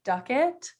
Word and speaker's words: Dukatt: Okay, so my name is Iris Dukatt.